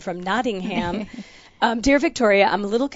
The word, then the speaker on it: Nottingham: And this is from Louise in Nottingham. Did I say that right? – Yes, you did. – That's Robin Hood. Is that Robin Hood? – Yes, it is. Sherwood Forest. Uh-huh. – Nottingham.